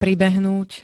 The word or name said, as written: pribehnúť